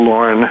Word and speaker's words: Loren: A is for my father's name, so it's Lauren, and it's spelled L-O-R-E-N instead of the more common female spelling now.